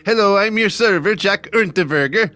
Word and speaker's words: Unterweger: Jack Unterweger. Whoa, I just had to say it like that. – Not even gonna try it. – Yeah, that's how I have to say it, German style.